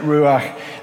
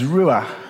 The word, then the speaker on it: ruach: The Hebrew word, word translated to spirit is ruach. I think that's how you say it. It's very breathy. There are a few different ways to translate ruach in the Hebrew, but they all are linked to this same idea that it's about a life force or an energy.